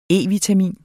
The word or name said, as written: E-vitamin